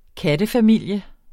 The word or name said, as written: kattefamilie